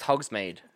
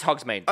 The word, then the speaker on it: Hogsmeade: It's pronounced Hogsmeade.